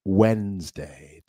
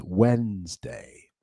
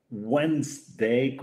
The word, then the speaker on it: Wednesday: Y otra gente, mucha gente dice Wednesday con una D, pero la D tampoco se pronuncia, ¿eh? Es Wednesday. Es más como si hubiera una Z al final, ¿eh?